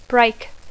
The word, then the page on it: break: English